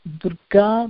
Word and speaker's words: Durga: My Facebook group is Holistic Wellbeing, but my Facebook ID is Durga Madhavi Mamidipalli.